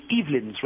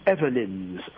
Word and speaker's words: Evelyn's: I have called it Evelyn's Restaurant, but apparently it is Evelyn's Restaurant, like forever, according to Lou, who helpfully provided me with some phonetics so that I should pronounce this correctly.